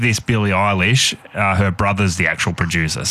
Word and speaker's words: Billie Eilish: Billie Eilish, Bad Guy.